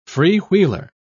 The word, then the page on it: freewheeler: British English